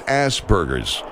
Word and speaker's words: Asperger's: All right, look, we often get into this difficulty, and it's not unusual, it's not unique to our show, a difficulty in pronouncing things, but it is Asperger's.